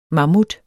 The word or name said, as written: MAMMUTTER